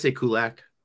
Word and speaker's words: Kulacz: Say it for me. – I say Kulak, but Kulak. Oh, you don't say the Z? – Oh boy. I've heard it's silent Z. I've also heard it should be pronounced as Q watch, but who knows?